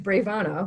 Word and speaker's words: bravana: The way to achieve bravana, which rhymes with nirvana, is that thing that scares you.